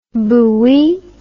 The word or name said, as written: bouilli